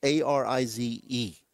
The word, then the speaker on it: Arraez: I tried to get the pronunciation, by the way. They on Baseball Reference, Arize. It doesn't make sense with the way his But I could talk.